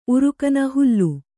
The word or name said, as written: ಉರುಕನ ಹುಲ್ಲು